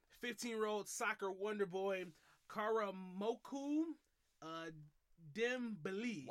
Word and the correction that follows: Karamoko: Why'd you say "koo"? It's Karamoko. – Karamoko Dembele.